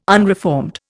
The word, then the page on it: unreformed: Indian